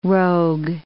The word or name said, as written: rogue